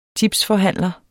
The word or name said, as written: tipsforhandler